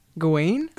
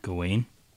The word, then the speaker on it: Gawain: Gawain? – Gawain. – I mean, that's Google. – Sir Gawain. – Sir Gawain. – But that's just the American pronunciation. What's the British one? – Do they have ye olde English?